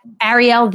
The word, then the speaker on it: Arielle: They can find me at Arielle This and That. That's A-R-I-E-L-L-E, This and That, because it rhymes with my last name, which is Nissenblatt.